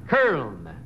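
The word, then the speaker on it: Köln: Curln. Curln. – Oh, could you spell it, Alan? – K-U-R-L-N. Oh, wait, no, that's the way you pronounce it. – Cologne. – Cologne. Cologne. – Yeah, Cologne. K-U-M-L-O-T-U-O-L-N.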